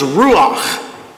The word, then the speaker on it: ruach: This particular word is a word, the word is pronounced ruach, and it means the creative divine presence of the Lord.